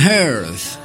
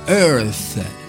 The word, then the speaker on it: earth: The tallest man on earth. Questa è la pronuncia giusta, earth, capito? Earth, ah, non earth, earth.